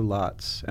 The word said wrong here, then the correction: Lottes: Am I pronouncing that correctly? Lotz? – Lotties. – Lotties, okay.